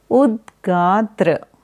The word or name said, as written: Udgata